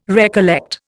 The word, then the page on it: recollect: Indian